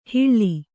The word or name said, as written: ಹಿಳ್ಳಿ